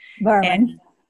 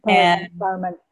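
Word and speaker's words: Bhaerman: The two books that we're going to talk about today are Spontaneous Evolution with Bruce Lipton and Steve B, whose last name I can't really pronounce. – Berman. – Brahman, thank you. – Berman.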